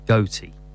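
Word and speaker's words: Gotye: Changed his music name to Now, I always get confused how to pronounce this. Goatee. That's what I understand is correct.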